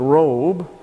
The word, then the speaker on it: rob: Let's take R-O-B, pronounced robe, and in addition to that, and that's your word, either abundance, multitude, or widespread, robe.